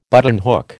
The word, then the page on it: buttonhook: American English